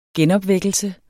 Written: genopvækkelse

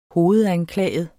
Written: hovedanklaget

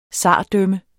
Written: zardømme

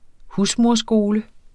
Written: husmoderskole

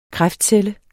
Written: kræftcelle

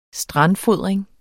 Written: strandfodring

